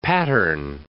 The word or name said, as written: pattern